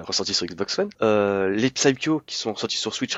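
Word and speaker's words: Psikyo: On prononce pas le P, les enfants. C'est Psycho.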